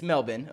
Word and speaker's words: Melbourne: I remember the first time I went there, I was talking to this chick, and I said Melbourne, and she was like, whoa, we're going to have to fix this really quick. It's Melbourne, okay? Say it properly.